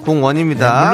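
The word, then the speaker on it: 공원소녀: 공연, 공원 소녀의 오 레이디 고 레이디 지금 네. 흘러나오고 있죠. – 발음 잘해주셔야 됩니다. 예, 공연입니다. 공연 아닙니다. 공원입니다.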